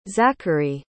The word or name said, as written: zackery